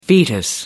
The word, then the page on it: foetus: American English